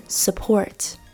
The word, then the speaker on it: support: Today 还有钱 Money M-O-N-E-Y Money 不是 Money Money Money and also the letter U, 还有字母 U, 像是 support, 支持 S-U-P-P-O-R-T, support, support 里面的 U, 它的发音同样是这个 schwa sound, support。